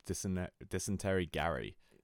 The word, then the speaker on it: Dysentery Gary: Because we're talking Dumpweed, we're talking Don't Leave Me, we're talking Aliens Exist, we're talking What's My Age Again, we're talking, I never had how to pronounce it, Dicentary Girl? – It's called Dysentery Gary. Is what it's called.